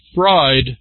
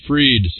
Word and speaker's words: Fried: The conundrum, is it freed or fried? Pending appeals and short of the electric chair, it's pronounced fried, not freed.